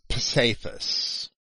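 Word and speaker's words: psephos: Cephas is P-S-E-P-H-O-S. I say Cephas because the E is an eta and not an epsilon. So I would pronounce that as an A-Y.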